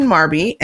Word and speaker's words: Marbie: It's at Queen Marby, and Marby is spelled M-A-R-B-I-E.